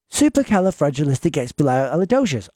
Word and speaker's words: Supercalifragilisticexpialidocious: She can almost say it fluently, but sometimes she gets forgetful of how to pronounce it. But she'll say to Alexa, "Alexa, play Super La Calama Flesher Flamachamanonomacanama Odabadodocious." And you know what? Alexa responds, "Playing Supercalifragilisticexpialidocious on Apple Music."